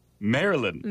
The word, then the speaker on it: Maryland: Don't you know your 50 states? Maryland. – Like Marilyn Monroe? – Kind of.